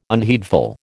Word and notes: unheedful: American English

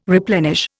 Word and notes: replenish: Indian

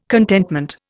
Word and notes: contentment: Indian

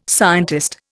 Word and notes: scientist: Indian

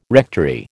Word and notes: rectory: American English

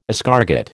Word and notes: escargot: American English

